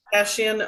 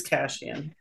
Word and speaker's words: Kashian: Kashian, always Kashian.